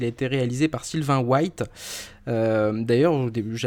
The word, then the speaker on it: White: Euh, Sylvain White, en fait, je pense qu'on prononce White puisque son père était un basketteur américain.